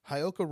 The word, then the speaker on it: Heyoka: I'm going to pronounce it like a white person. Heyoka. – Okay. – Because it's spelled H-E-Y-O-K-A. Heyoka.